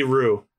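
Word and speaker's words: Roux: And the fat guy who's always eating meat is Lucky Rokes. – Lucky Roo. It's French.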